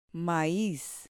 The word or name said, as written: maiz